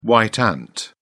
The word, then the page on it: white ant: British English